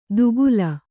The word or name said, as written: ದುಕೂಲ